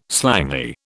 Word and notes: slangy: British English

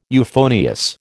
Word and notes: euphonious: American English

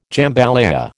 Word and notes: jambalaya: American English

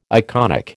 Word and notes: iconic: American English

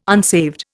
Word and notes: unsaved: Indian

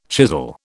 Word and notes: chisel: British English

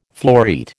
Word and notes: floreat: American English

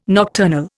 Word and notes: nocturnal: Indian

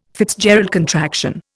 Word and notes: FitzGerald contraction: Indian

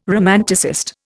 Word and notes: romanticist: Indian

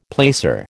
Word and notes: placer: American English

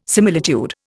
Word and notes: similitude: Indian

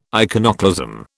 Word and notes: iconoclasm: British English

